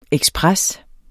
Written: ekspres

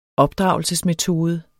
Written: opdragelsesmetode

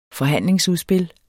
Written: forhandlingsudspil

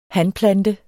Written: hanplante

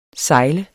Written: sejle

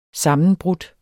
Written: sammenbrudt

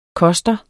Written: koster

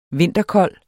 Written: vinterkold

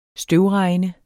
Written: støvregn

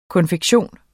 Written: konfektion

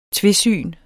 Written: tvesyn

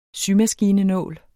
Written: symaskine

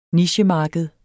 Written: nichemarked

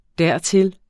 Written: dertil

